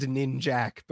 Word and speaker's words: Ninjak: It's like spelled ninjak, but apparently it's not pronounced that way.